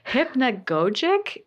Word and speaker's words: Hypnagogic: Hypnagogic is the way that I think we landed on.